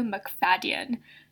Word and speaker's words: MacFadyen: It's we struggled with how to pronounce this, but according to James Corden, it's Matthew MacFadyen. So it's phonetic.